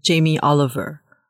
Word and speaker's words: Jamie Oliver: 过去他可能访问过李安，访问过，哎，你你直接讲好了，这个人的名到底怎么念啊？Jamie Oliver。